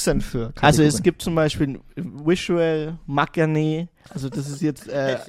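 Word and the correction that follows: maknae: Ähm, Magne heißt das ja eigentlich mal und spricht ja das.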